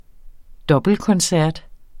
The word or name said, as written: dobbeltkoncert